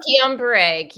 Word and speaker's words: Guillain-Barré: You've had a number of autoimmune diagnoses yourself, including am I saying this right? Guillain-Barré syndrome? – Oh, Guillain-Barré. Guillain-Barré, yes.